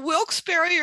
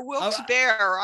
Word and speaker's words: Wilkes-Barre: Well, Wilkes-Barre or Wilkes-Barre, I've heard it pronounced both ways.